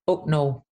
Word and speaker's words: Hucknall: Well, first of all, let's get this pronunciation of Hucknall right. So would you like to hear the absolute gold standard of I'd love nothing more. – Hit how the locals pronounce it. So it's Hucknall.